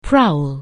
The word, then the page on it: prowl: anglais